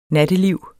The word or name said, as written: natteliv